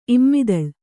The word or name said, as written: ಇಮ್ಮಿದಳ್